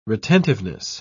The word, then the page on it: retentiveness: British English